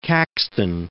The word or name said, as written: Caxton